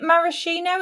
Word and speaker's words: maraschino: And then it has, now, is it maraschino? It's pronounced as is the cherry.